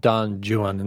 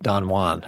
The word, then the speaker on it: Don Juan: Tell us what Don Juan is about, and why is it called Don Juan instead of Don Juan, as I've been pronouncing it my entire life.